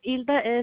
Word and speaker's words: Hilda: Hilda is H-I-L-D-A. Cerda is C-E-R-D-A. – And the last name? – It's C-E-R-D-A.